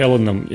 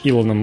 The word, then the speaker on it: Элон: Э, значит, компания SpaceX, э, возглавляемая Элоном э, слэш Илоном Маском, я до сих пор, кстати, не знаю, как правильно говорить. – Я говорю Элон.